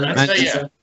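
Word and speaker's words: Mensa: Menzna. I'm pronouncing it right? Menzna? – Yeah. Menzna.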